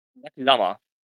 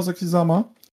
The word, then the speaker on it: Sakisama: Bestehend aus Mai Saint-Michel und warte, wie wird sie nochmal ausgesprochen? – Sakisama. – Sakishama, genau, Sakisama.